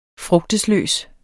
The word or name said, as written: frugtesløs